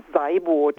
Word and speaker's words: Seiboth: Alors, vous êtes Ulrike Seboth, c'est comme ça qu'on prononce ? – Non. – Comment qu'on dit ? – C'est un nom allemand. Je m'appelle Ulrike Seiboth.